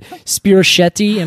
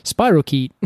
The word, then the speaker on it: spirochete: Spirochetti. And I said, I just like pronouncing it that way. And everyone's like, I'm pretty sure it's like spirochete.